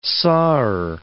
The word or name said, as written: sawer